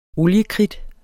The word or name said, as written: oliekridt